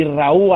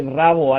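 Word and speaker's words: Rauw: Hombre, todos esperábamos el reencuentro entre Rosalía y Raúl Alejandro, o como dice mi madre, Rabo Alejandro, porque no sabe pronunciar Raúl con W, ella le llama Rabo.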